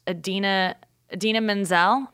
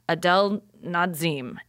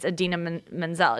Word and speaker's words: Idina Menzel: But he said, and here to perform the, you know, song from Frozen is Adele Nazim. I mean, not even close. He said Adele yeah. Nazim, and it's Idina Menzel.